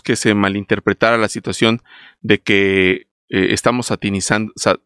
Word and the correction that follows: satanizando: ¿cómo se dice? Satanizando, perdón, satanizando.